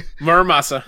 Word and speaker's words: Muramasa: Muramasa.